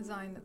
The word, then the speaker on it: Xine: Zine, as in wine. – Mm-hmm. Zine, so it starts with an X and rhymes with wine.